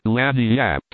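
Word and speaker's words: lagniappe: Apostrophe apostrophe they pronounce it Laniap. It is Spanish, so they said.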